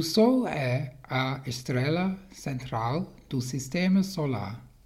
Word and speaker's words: estrela: And the pronunciation of star, estrela. E S in Portuguese is like estrela, yeah.